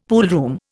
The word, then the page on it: poolroom: Indian